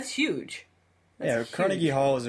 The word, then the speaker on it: Carnegie Hall: Carnegie Hall.